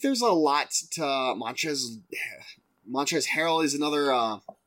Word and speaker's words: Montrezl: It's Montrezl Harrell.